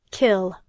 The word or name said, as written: kill